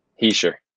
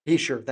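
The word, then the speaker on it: Hischier: Heischer. – Heischer, thank you.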